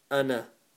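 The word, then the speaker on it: أنا: The word ana, we don't pronounce the alif at the end. So it's just ana.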